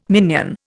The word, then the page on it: minion: Indian